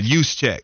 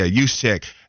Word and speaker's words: Juszczyk: I just looked up the pronunciation of his name. I think I said it right anyway, but Juszczyk, because that's how the 49ers say. Yeah, Juszczyk.